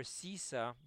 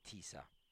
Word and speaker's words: Sisa: However, Sisa, by the way, I'm using the Ashkenazic European pronunciation. Uh, um, modern day Hebrew and Sephardim would usually say Tisa, can also be translated to mean lift.